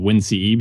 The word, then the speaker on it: WinCE: Windows Mobile, and then it was called WinCE before that. It's great that they named a product whose, if you pronounce this acronym, is Wince.